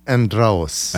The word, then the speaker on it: Andraous: And Andraous, is that how it's pronounced in Arabic? I'm trying to do it right. – Andraous.